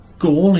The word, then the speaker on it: Gaul: Oh, northeast of Gaul, is that how you say it? – Gaul, yeah.